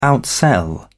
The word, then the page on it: outsell: British English